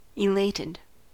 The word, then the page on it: elated: American English